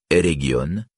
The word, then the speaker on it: Eregion: G a toujours un son dur comme dans l'anglais get. Dans Region, Eregion, le G n'est donc pas celui du français région, ni celui de l'anglais region. Et la première syllabe de Ginglith se prononce comme dans le mot guigne et non comme dans gingembre.